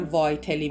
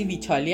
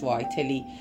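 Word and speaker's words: Vitale: خب وایتلی کلن، حالا البته من وایتلی میگم، به فارسی ویتالی هم میگن ولی تلفظ اسمش وایتلی گفته میشه.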